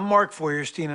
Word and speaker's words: Mark Feuerstein: Mark Feuerstein? How's that pronounced? – Hi, I'm Mark Feuerstein, and I play Dr. Hank Lawson on Royal Pain.